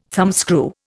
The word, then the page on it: thumbscrew: Indian